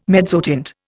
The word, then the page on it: mezzotint: Indian